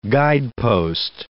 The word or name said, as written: guidepost